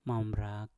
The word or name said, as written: mambrak